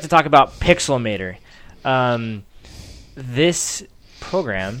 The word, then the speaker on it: Pixelmator: I've always pronounced it Pixelometer. – Well, you added a letter to it. But it is Pixelmator.